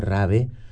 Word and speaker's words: rave: hablando de las fiestas rave o rave, como dicen en España, que son enérgicos con la pronunciación en español de todas las palabras.